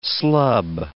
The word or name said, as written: slub